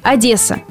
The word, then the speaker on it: Одесса: Словарь собственных имен русского языка настаивает на мягком произношении, то есть Одесса. Местные жители также убеждены, правильно только произношение через «е». А жесткое произношение считается более украинским.